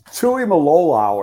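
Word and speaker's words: Tuimoloau: Okay, I don't know how to say the first one. It's Tui Malolau or something like that. – I think you nailed it.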